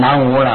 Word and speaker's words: 南无: 南无了，南无西方极乐世界阿弥陀佛，那你就念成南无了，那就完全不对了。